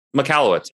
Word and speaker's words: Michalowicz: I don't even know how you say his last name. – Yeah, I think you said it right. Michalowicz.